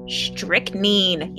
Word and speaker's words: strychnine: Specifically, strychnine. Yes, I got it. I said it right.